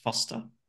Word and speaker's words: Forster: It's spelt Forster, but it's pronounced Foster.